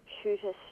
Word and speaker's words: Shuter: and that's at 28 Shooter Street, Moonee Ponds. That's spelled S-H-U-T-E-R, and it's just a short walk from Puckle Street, so you can easily get there by train or tram.